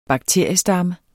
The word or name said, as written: bakteriestamme